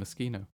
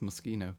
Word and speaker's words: Moschino: Actually, it's called Moschino. – Moschino. – Did you know that? – No. – Yes. Yeah, pronounced Moschino.